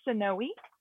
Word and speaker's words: Sanoe: And yes, nobody can say my name, period. So it is Sanoe. It's Native American and Hispanic.